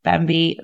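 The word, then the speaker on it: Bamby: and we can recommend Bambi Media, and they are fantastic.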